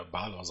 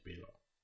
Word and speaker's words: Balor: Auch, ich glaube, irgendjemand hat auch in einem WWE-Podcast gesagt, dass wir ja Balor falsch aussprechen, weil wir ja Balor sein und nicht Belor.